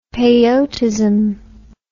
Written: peyotism

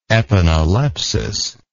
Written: epanaleptic

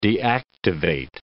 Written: deactivate

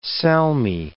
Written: salmi

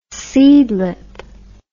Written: seedlip

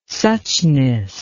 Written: suchness

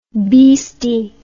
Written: beasty

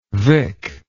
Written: vic

